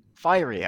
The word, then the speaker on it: Fiery: Is it Fiery Cushman? – Or Fiery Cushman? – Fiery, okay, Fiery Cushman.